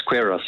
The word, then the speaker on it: Queiros: It's Queiros, yes.